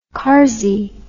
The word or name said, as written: kazi